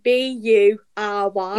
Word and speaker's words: Bury: So it's the place that's spelled B U R Y. – Yeah, so it's Bury.